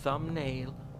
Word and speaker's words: thumbnail: thumbnail که حالا به صورت انگلیسی thumbnail thumbnail به این شکل تلفظ میشه